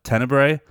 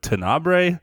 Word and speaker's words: Tenebrae: I don't even know why I'm talking about this, but I just felt embarrassed because I talk about Dario Argento's film Tenebrae, which is pronounced Tenebrae and not, as I insist in the episode, Tenabre.